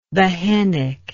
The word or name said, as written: behenic